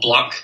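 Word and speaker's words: 6LACK: So the first one I've got on my list is called Black. It's spelled with a six instead of a B, so it's spelled like six lakh.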